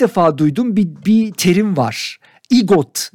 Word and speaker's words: EGOT: EGOT. Peki EGOT olarak okunuyor. İşte EGOT aslında baş harfleri alırsak Emmy, Grammy, Oscar ve de Tony.